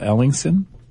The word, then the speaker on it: Ellingsen: Ellingsen? – Yes.